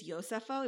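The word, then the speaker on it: Josepho: At a camp, which is Camp Yosefo, which I do think it's Yosefo, even though it's spelled Josefo.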